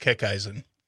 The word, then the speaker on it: Kickeisen: It's pronounced Kickeisen. – Kickeisen. – Yep. – Kickeisen. – Slightly German.